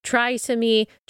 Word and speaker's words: trisomy: Well, her child, her baby, was diagnosed with something called trisomy 18. Some people might pronounce it trisomy 18, a rare chromosomal disorder, which is usually, but not always, fatal.